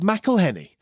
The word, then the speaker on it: McElhenney: It's McElhenney. – McElhenney. – McElhenney. – McElhenney. – If there was doubt, now there won't be any. It's McElhenney.